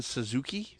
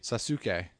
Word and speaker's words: Sasuke: Is it Itachi and Sasuke? – Sasuke. – Okay, Sasuke. Oh, I'm sorry. – Just telling you how it's pronounced.